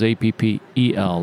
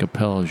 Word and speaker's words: Appel: Shoot me an invitation on LinkedIn, David Apple at Intact. I'd love to get engaged. – And just so it's spelled how? – Oh, sorry. David and Apple is APPEL. Looks like Appel. Je m'appelle Appel.